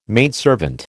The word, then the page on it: maidservant: American English